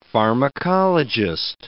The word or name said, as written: pharmacologist